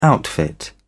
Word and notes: outfit: British English

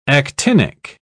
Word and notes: actinic: British English

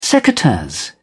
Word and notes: secateurs: British English

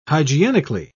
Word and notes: hygienically: British English

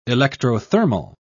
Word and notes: electrothermal: British English

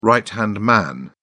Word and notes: right-hand man: British English

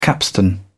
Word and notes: capstan: British English

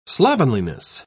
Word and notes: slovenliness: British English

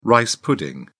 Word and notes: rice pudding: British English

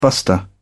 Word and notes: buster: British English

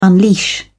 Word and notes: unleash: British English

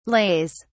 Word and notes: lays: British English

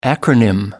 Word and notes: acronym: American English

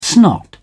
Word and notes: snot: British English

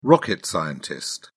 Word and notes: rocket scientist: British English

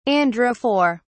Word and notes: androphore: British English

